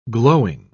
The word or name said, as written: glowing